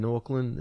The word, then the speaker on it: Auckland: He agreed to fight in Auckland.